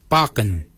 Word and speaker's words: baginy: For beard, we say barkin. Barkin is spelled B-A-G-I-N-Y.